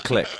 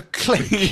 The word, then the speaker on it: clique: Well, in the US, it's a clique. – At this moment, I take particular pleasure. I have made two mispronunciations, one in the last show and one this evening, off air. – Do me a favour. Look out the window behind you. – Huh? – Can you look out the window behind you? – I don't think so. Yeah. – Can you see the stars and stripes? No. You're in England. It's a click.